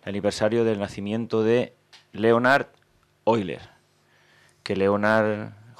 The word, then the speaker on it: Euler: Bueno, pues Leonhard Euler, se escribe Euler, todo el mundo hemos dicho Euler, por lo visto es Euler, vamos a llamarle Euler, que es realmente como se pronuncia.